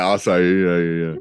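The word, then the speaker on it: açaí: They could make a, what is it, acai? Is that how you pronounce the berries? – Yeah, acai. Yeah.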